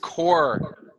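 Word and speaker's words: corps: The S is silent, core. But there's, you don't really hear the P either. It's not the P core. And the S are silent.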